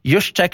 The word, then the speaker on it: Juszczyk: Juszczyk, czyli Kylie Juszczyk. Pisze się jak Juszczyk, ale oni nie wiedzą, że to trzecie Y.